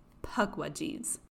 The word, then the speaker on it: Pukwudgie: But the mischievous Pukwudgies They, the envious little people, they, the fairies and the pygmies, plotted and conspired against him. That's right. Today, I'm going to talk about Pukwudgies.